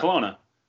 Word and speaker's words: Kelowna: How do you say that? You got to know. – Where? – Kelowna? – Kelowna. Yeah, Kelowna.